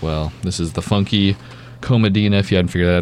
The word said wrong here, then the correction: Funky Cold Medina: Did I say that right? Comadina? – Funky Cold Medina.